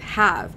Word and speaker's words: have: but that doesn't work in the high frequency word have, because it's making that short A vowel sound.